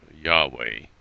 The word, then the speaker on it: Yahweh: So it was Y-H-W-H. Later pronounced Yahweh, probably irreligiously so, but nonetheless, that's what it was. The Lord, L-O-R-D in caps, is Y-H-W-H,